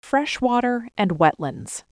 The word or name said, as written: Freshwater & wetlands